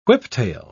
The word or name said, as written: whiptail